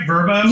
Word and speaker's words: Vrbo: you got to let Vrbos. Am I saying it right? Vrbo? – You are Vrbo.